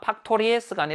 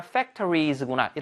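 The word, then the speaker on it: factories: 팍토리에스가 아니라 팩토리즈구나.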